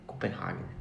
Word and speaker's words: Copenhague: Aliás, Copenhague não, né? Copenhague.